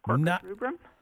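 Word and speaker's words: Quercus rubra: Northern red oak, Corker's rubrum. – No Corker's rubra today. Congratulations on the correct pronunciation of the botanical name of red oak.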